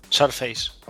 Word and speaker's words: Surface: Hombre, en iPad no lo sé, pero había una versión de Torchlight, creo que eran dispositivos Surface y demás, que funcionaba bastante bien. – ¿Surface? – Correcto. Surface.